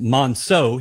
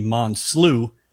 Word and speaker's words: Monso: My name is spelled or pronounced Monso. He called me Monslu for 6 years.